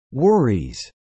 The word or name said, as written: worries